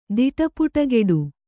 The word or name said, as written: ದಿಟಪುಟಗೆಡು